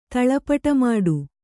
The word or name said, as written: ತಳಪಟಮಾಡು